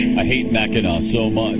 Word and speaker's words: Mackinac: Oh, no, no Mackinac Island. First of all, yeah. – yeah. It's spelled, why do they spell it Mackinac when it's Mackinac?